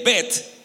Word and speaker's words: Bet: Y Bet, que se lee Bet, quiere decir casa.